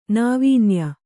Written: ನಾವೀನ್ಯ

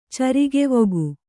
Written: ಚರಿಗೆವೊಗು

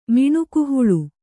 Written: ಮಿಣಕುಹುಳ

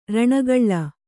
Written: ಋಣಗೂಳು

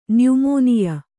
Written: ನ್ಯುಮೋನಿಯ

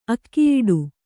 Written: ಅಗೆಯೊಡೆ

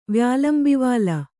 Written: ವ್ಯಾಲಂಬಿವಾಲ

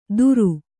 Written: ದುರು

